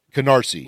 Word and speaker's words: Canarsie: Well, as we wrap things up, there's one conversation when you started, did I say Carnesi? Did I say it right? – Canarsie. – Now you make me think. Canarsie, sorry.